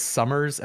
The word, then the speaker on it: Somers: My real last name is Summers, S-O-M-E-R-S, just like Somerville.